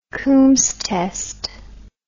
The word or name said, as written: coombs test